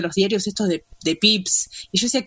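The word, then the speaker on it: Pepys: yo le decía Pepis, pero hoy buscando me enteré que se dice Pips el apellido, Así que sigo Pepis es porque me, traiciona mi pronunciación mala anterior.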